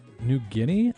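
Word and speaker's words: Nu Genea: In the background right now, we have this track, Kare, by New Guinea. I'm pretty sure is how I'm supposed to pronounce this. It's N-U space G-E-N-E-A.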